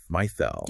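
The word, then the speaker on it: mithāl: In Arabic, the mithal, and that's pronounced mithal.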